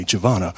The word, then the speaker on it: Januwoina: It's pronounced simply Javana, but it's spelled J-A-N-U-W-O-I-N-A. It makes no phonetic sense whatsoever.